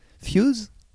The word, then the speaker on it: Thews: T-H-E-W-S. Fuse, oui. – D'accord. – C'est bien quoi, Fuse? – Pas la moindre idée. – En fait, c'est un jeu de mots sur Almighty Few.